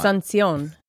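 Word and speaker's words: Asunción: Almost like ascension. – Almost, but it's not. – Asuncion.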